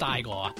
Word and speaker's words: Igor: It's pronounced Igor.